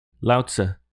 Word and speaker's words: Laozi: Laozi? – Laozi.